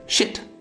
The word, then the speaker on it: shit: 比如在英语里，你发出 shit 这样的声音，它所对应的意思是一张纸的张，但是念得短促一些 shit，词义就变了，变成了一坨翔。